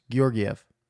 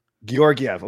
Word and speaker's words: Georgiev: Is it Georgiev or Georgiev? Is it hard G's or soft G's? What do you say? – A hard G to start. It's all hard G's. Georgiev. – Georgiev.